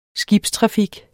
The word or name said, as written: skibstrafik